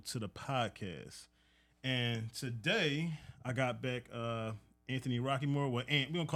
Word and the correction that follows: Rockemore: First of all, the E in Rockemore is silent. – Oh, I'm sorry. – It's Rockemore. – I'm sorry. Anthony Rockemore, my good friend.